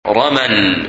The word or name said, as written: Ramal